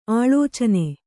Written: ಆಳೋಚನ

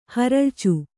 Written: ಹೊರಳ್ಚು

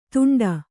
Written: ತುಂಟ

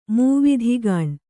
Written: ಮೂವಿಧಿಗಾಣ್

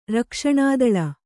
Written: ರಕ್ಷಣಾದಳ

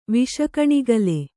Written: ವಿಷಕಣಿಗಲೆ

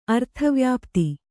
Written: ಅರ್ಥವ್ಯಾಪ್ತಿ

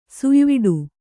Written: ಸುಯ್ವಿಡು

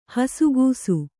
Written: ಹಸುಗೂಸು

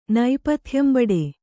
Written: ನೈಪಥ್ಯಂಬಡೆ

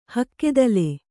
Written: ಹಕ್ಕೆದಲೆ

